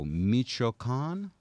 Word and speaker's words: Michoacán: Michoacán. Okay. It's west central. Thank you for saying it with an accent, too.